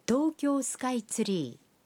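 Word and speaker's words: টোকিও স্কাইট্রি (東京スカイツリー): টোকিও স্কাইট্রি কথাটির জাপানি উচ্চারণ হল টোকিও স্কাইট্রি।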